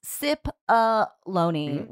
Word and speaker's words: Cipollone: I'm sorry, Jonathan spelled his name out phonetically for us, and it's Cipollone, which rhymes with testimony.